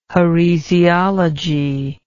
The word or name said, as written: heresiology